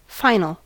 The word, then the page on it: final: American English